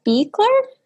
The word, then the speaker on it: Buecheler: And our guest today is Christopher Buecheler.